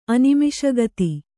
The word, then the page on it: ಅನಿಮಿಷಗತಿ: Kannada